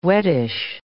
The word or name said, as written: wettish